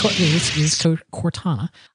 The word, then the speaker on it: Cortana: So it's Cortana. Now, how would you pronounce the name? That is correct.